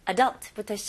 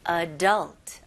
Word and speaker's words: adult: 네, 처음에는 일단 adult부터 시작할게요. 발음부터 할게요. 여기서는 adult. Adult. Okay. 강세가 어디 들어가는지 혹시 들리시나요? 네, 두 번째 음절에 들어갑니다.